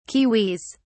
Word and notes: kiwis: British English

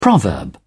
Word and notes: proverb: British English

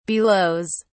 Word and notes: belows: British English

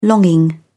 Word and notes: longing: British English

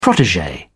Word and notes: protege: British English

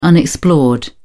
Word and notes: unexplored: British English

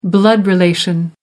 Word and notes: blood relation: American English

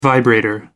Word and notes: vibrator: American English

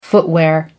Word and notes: footwear: American English

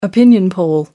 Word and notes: opinion poll: American English

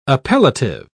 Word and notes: appellative: British English

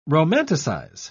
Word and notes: romanticise: British English

